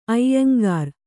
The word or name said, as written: ಐಯಂಗಾರ್